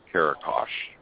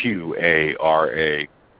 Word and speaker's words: Qaraqosh: I call it Qarakash. Now, I don't know how you pronounce it over there in Iraq. It's Qaraqosh. I call it Qarakash.